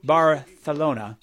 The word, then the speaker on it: Barcelona: By the way, Fontaine's DC has officially announced they'll be one of the headliners at the Primavera Sound Barcelona Festival, or Barthelona. That's how they say it there.